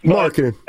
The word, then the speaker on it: Markkanen: I was even looking at Markkanen. I can't if I pronounce his name right. – Yeah, Markkanen.